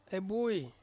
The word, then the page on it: bui: Ansus